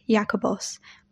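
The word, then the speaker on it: Jacobos (Ἰάκωβος): In Aramaic, his name would have been pronounced Zavdi, and in Greek, Jacobos.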